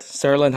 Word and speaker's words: Saraland: It's pronounced Saraland, correct? Saraland High School. – Yes, Saraland.